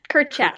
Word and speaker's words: Kurchak: It's a little more of an ack. It's actually technically spelled. Yeah, Kurchak.